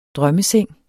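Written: drømmeseng

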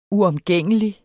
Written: uomgængelig

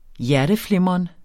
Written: hjerteflimren

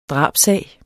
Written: DRABSSAG

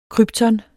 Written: krypton

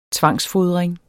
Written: tvangsfodring

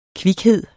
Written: kvikhed

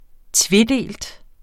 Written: tvedelt

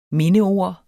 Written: mindeord

